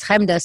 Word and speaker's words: Chemda: My name is Chemda, so if you know how to spell that, you can find anything I've ever done just by first name.